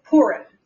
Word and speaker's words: Purim: So it's Purim.